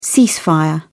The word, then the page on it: ceasefire: British English